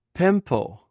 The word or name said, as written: pempw